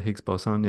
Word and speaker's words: Higgs boson: Yeah, the Higgs boson.